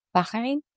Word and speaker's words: Bahrain: Did I say that correctly? – Bahrain.